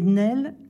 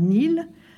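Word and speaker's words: Néel: La prononciation correcte est Nel et non pas Nil, explique-t-elle elle-même dans une lettre à son éditeur.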